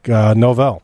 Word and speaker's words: Novell: Gary Novell, N-O-V-E-L, like Novell.